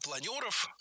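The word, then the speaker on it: планёров: Планеров, планер, и это правильно. Но и планер с ударением на первом слоге и с «е» во втором тоже литературная норма. Это как раз тот самый случай, когда возможны варианты и с «е», и с «ё».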